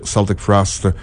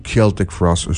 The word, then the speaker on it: Celtic Frost: Et nous avons ouvert avec Celtic Frost ou Celtic Frost, selon ce qui vous semble bon de prononcer, parce que les deux sont bien.